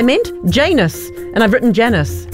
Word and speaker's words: Janus: It rhymes with anus. It's Janus.